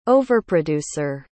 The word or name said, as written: overproducer